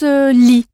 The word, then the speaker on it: gli: Et si vous voulez dire je lui donnerai, en parlant d'un homme, je donnerai à lui, vous emploierez li, qui s'écrit G-L-I et se prononce li, un peu comme dans Lyon.